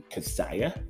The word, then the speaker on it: cachaça: So I want to pronounce that right. I used Google. That was me using the internet correctly for a change. Uh, Cachaça.